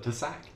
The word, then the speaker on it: TASAC: Is that how you pronounce it? Tasac? – Yes, that's my own acronym.